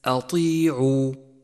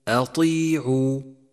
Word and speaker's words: أطيعوا: ثالثا، احذر من تفخيم الهمزة في كلمة أطيعوا، أطيعوا، فمن الخطأ الشائع تفخيمها في مثل هذا الموضع لأن بعدها حرف مفخم وهو الطاء، فرققها وفخم الطاء أي هكذا أطيعوا، وخطأ أن تقول أطيعوا، أطيعوا، والصواب ترقيق الهمزة.